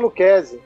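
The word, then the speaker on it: Lucchesi: Patrícia Luquezzi. Luquezzi. – Luquezzi, obrigado. – É, Luquezzi. Obrigado pela pronúncia. Para mim era Luca, Luca, Lu, é Lucacelli. É Luquezzi. Luquezzi.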